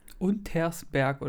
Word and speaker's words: Untersberg: Und ich dann bei der Recherche gemerkt habe, dass wir zwei Horsties, ich habe es ja auch falsch gesagt, es einfach falsch ausgesprochen haben. Also wir haben ein S vergessen mitzusprechen. Untersberg oder wie? – Richtig, sehr gut.